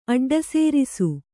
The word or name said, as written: ಅಡ್ಡಸೇರಿಸು